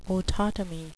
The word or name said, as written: autotomy